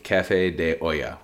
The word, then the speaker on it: Café de Olla: Option one was for $75, you got two bottles of Prop and a bottle of Café de Olla, which, by the way, that is how you pronounce it, not Ola, not Ala, not whatever the hell we called it.